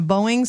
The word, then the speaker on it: Boeing: A United's Airlines pilot was flying a Boeing 787. Did I say that right, Matt? – Yep, you did. Proud of you.